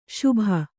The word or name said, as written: ಶುಭ